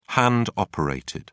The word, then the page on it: hand operated: British English